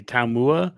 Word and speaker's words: Taumua: T-A-U-M-U-A. Taumau. – T-A-U-M-U-A. – Mazkua. Yeah, it's another UA.